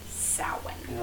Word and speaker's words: Samhain: Yeah, but any true Celtic-based languages, I mean, your Welsh people, your Gaelic-speaking people, your Scots-speaking people, your Irish-speaking people, they'll all be very quick to inform you that it is pronounced Samhain.